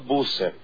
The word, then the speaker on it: Busser: El apellido es Busser, así como suena.